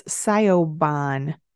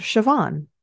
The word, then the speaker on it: Siobhan: And I saw that her name was, I'll spell it for you, Siobhan, which phonetically, if you're the average American, probably would say, oh, it's Siobhan. But I happen to know because I have Irish friends in my network, and because I watched Orphan Black, and there was a character of this name on the show, I know that this word, Siobhan, is pronounced Siobhan.